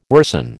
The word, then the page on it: worsen: American English